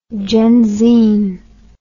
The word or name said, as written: genzine